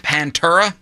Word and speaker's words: Pantera: I believe the band is pronounced Pantera.